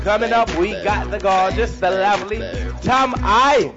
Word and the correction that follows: Tammy Chin: Actually, that is pronounced Tammy Chin.